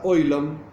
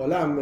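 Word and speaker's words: Olam (עולם): Ahora bien, la palabra Oilom, Oilom, Olam en hebreo común, Olam significa mundo, está directamente relacionada con la palabra Helem.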